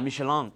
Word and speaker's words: Michelin: Michelin.